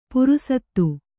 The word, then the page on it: ಪುರುಸತ್ತು: purusattu